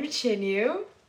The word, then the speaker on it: Qianyue: Yeah, her name is like spelled with a Q. – Yeah. – Q U. – No. – Wait, Q I A N Y U E.